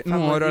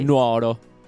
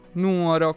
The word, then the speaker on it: Nuoro: Si dice Nuoro, ragazzi. – Marcello, sì. Non Nuoro. – Nuoro, con l'accento sulla prima.